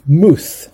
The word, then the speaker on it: Muth: Muth, that's spelled M-U-T-H, Muth, an economist writing in 1961 about the theory of price movements.